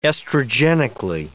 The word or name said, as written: estrogenically